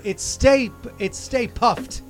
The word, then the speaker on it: Stay Puft: And again, we say it wrong. It's Stay Puffed, right? – Puffed with a T.